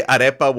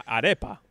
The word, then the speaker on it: arepa: Why, arepa? What's the problem with that? What I don't know. – An arepa? I mean, come on. I'm from Uruguay. We pronounce it the right way.